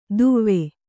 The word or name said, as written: ದುವ್ವೆ